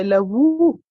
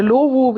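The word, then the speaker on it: Lovoo: Und meine jetzige Freundin, die habe ich tatsächlich bei LaVou kennengelernt. – Okay, ja. – Oder LoVou, wie es manche aussprechen.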